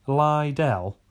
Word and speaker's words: Liddell: Couldn't even pronounce Chuck Liddell's name correctly. Chuck Liddell? Chuck Liddell? Who's that? That's no one. It's Liddell. Everyone knows it's Liddell.